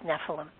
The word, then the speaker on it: Nephilim: I think there's another way to pronounce that, but I go with Nephilim.